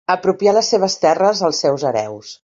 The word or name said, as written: hereus